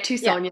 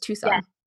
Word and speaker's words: Tucson: And like you go to, like, is it, do you say you call it Tucson? – Yeah, Tucson. Yeah. – It's spelled like Tucson, isn't it? But it's Tucson.